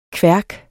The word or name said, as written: kværk